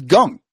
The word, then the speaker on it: Kang: His name was Gung. Maybe somebody who understands other languages better than me or something can explain why when you translate somebody's name from Korean and it's Gung, you spell it K-A-N-G.